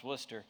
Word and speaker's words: Worcester: It's actually spelled Worcester, but it's pronounced Worcester, Massachusetts.